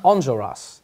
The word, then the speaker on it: Enjolras: Onjoras. – Angel. – Onjoras. On. On. On. It's like that French on. thing.